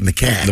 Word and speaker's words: macaque: It's macaque. – The macaque monkey, yeah. – Okay, I got macaque. Yeah, okay. I got this. – Rhymes with backpack.